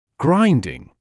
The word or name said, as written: grinding